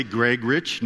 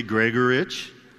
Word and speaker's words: Gregurich: But then you have Gregorich. My family doesn't even agree on how to say it. Some say Gregorich, no you. Others say Gregorich.